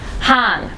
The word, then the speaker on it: Han: Today my very, very, very fabulous guest is Han, and it's H-A-N, silent H, because I always get it transposed in my brain and write it the other way.